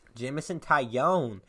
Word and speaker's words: Jameson Taillon: Jameson Taillon to the Yankees in exchange for 4 minor league prospects.